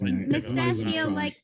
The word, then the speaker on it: mustachio: Mustachio, like pistachio, except mustachio.